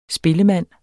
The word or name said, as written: spillemand